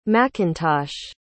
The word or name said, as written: mcintosh